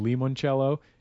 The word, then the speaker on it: limoncello: By the way, I've been corrected by several people for saying limoncello just because it's Italian and has an I, which would be pronounced as an E in Italian.